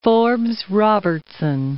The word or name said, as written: Forbes-Robertson